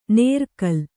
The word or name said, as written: ನೇರ್ಕಲ್